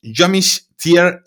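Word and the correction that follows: Joachim Trier: Bueno, lo primero de todo es que te has complicado mucho con eh, eh, Joaquín Trier, que es un nombre bastante sencillo de pronunciar, has querido poner acento ahí noruego.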